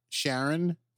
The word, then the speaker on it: Charon: Or Charon. Uh-huh. No, stop. because Charon, or Charon, depending on how you want to pronounce it,